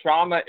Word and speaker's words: Kshama: And remember, Kshama is spelled with a K S H A M A.